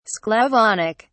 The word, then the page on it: sclavonic: British English